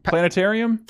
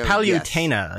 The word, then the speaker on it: Palutena: Planetarium. – Palutena.